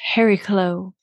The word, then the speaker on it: Chariklo: Lately, I've been working with a new asteroid called Chariklo. It's actually pronounced Heriklo in Greek.